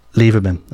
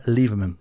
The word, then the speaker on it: Lieberman: Robert Flack released it in 1972, and then we had Loria Liberium. I can't even pronounce that. Do you know how to pronounce that? Hang on, I'm going to ask someone off air here. Do you know how to pronounce that? – Lieberman. – There you go. Loria Lieberman, yeah? There you go. I said it right that time.